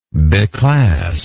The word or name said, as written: beclasp